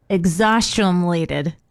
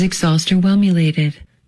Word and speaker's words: exhausterwhelmulated: It's spelled E-X-H-A-U-S-T E-R-W-H-E-L-M U-L-A-T-E-D. – Exhaustionated.